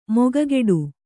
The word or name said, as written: ಮೊಗಗೊಡು